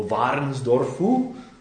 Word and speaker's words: Varnsdorfu: Nebo Varnsdorfu, správně, mě žena poučila, jo? Já jsem říkal, jak to ti Němci můžou vyslovovat, jako Varnsdorf, když to nejde přes pusu. Ona říká, no to jsou dvě slova, to je Varnsdorf.